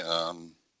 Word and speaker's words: Al-Ghazali: I may probably pronouncing it wrong. Is it Al-Ghazali? – Al-Ghazali the foundation. – Yes.